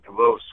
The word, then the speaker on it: Davos: When I was there at the White House after Placid, I'd written a letter in January from Davos, Switzerland. That's how you say that, by the way. – Is that how you say it?